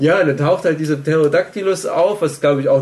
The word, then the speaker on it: Pterodactylus: Pterodactylus Pterodactylus, Pterodactylus. – Und ähm, ja, The P is silent, weil ja auch niemand das richtig weiß, weil es damals halt einfach.